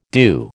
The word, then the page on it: due: American English